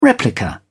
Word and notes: replica: British English

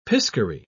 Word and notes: piscary: British English

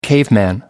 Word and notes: caveman: American English

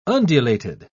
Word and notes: undulated: British English

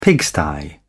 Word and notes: pigsty: British English

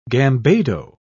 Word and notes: gambado: British English

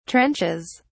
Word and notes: trenches: British English

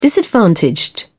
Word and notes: disadvantaged: British English

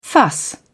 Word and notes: fuss: British English

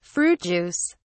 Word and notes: fruit juice: British English

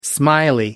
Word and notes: smiley: American English

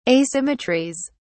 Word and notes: asymmetries: British English

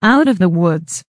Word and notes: out of the woods: American English

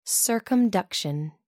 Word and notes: circumduction: American English

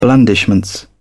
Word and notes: blandishments: British English

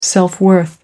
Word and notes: self-worth: American English

